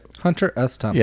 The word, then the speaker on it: Hunter S. Thompson: am I saying that right? – Hunter S. Thompson.